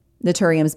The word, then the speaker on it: Naturium: My next favorite discovery of 2023 is the brand Naturium, which is a super affordable, high quality skincare brand sold at Target. Naturium is spelled N-A-T-U-R-I-U-M. IUM, Naturium.